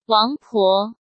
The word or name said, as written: Wang Po